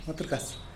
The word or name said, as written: matrugas